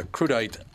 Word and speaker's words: crudité: Fetterman, who is running against Oz for a Senate seat in Pennsylvania, mocked Oz for an odd campaign video regarding a crudite tray.